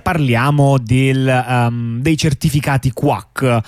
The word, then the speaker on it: QWAC: si scrive Q. Eh, WAC, eh, però quindi l'ovvia pronuncia è quack come, eh, le, mm, come, come chi, le, papere.